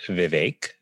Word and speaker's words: Vivek: Uh, so I can tell you that my first name is pronounced Vivek. The VI is like the VI in Victor. The VEK rhymes with lake.